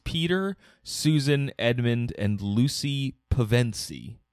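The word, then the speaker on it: Pevensie: No, it's Pevensey, I believe. – Pevensey.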